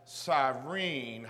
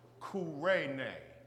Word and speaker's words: Cyrene: Those of European and Western descent pronounce the name of my country as Cyrene. However, it's really pronounced Kurene.